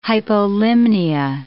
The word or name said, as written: hypolimnia